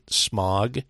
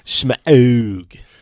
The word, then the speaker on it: Smaug: I've been pronouncing it wrong my whole life. I pronounced it as if it was the pollutant smog, but it is, according to the film, Smaug.